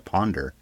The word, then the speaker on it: Pounder: They pronounce it Ponder, but it's spelled Pounder. – Colin Pounder, but it's pronounced, as he says it, Ponder.